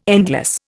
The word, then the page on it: endless: Indian